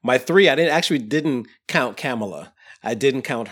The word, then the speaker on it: Kamala: Beto O'Rourke, Peter Buttigieg, Joe Biden, Elizabeth Warren, Kamala Harris, which is how we're supposed to pronounce her name.